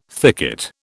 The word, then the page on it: thicket: British English